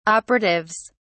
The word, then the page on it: operatives: British English